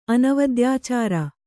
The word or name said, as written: ಅನವದ್ಯಾಚಾರ